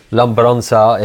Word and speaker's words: Lembrança: And we released a song called Lembrança. That was really American for me to pronounce it like this. Lembrança, it's in Portuguese.